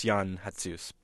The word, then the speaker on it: Jan Hatzius: Am I pronouncing that right? – I think it's Jan Hatzius.